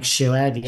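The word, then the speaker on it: Shouhed: How do you pronounce his last name? Shoed? – Mike Shoed, yeah.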